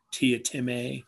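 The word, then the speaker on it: Teatime: There's a little joke about how he always corrects people. It's pronounced Tia Time. – Yeah. – But it, you know, I pronounce it Tea Time.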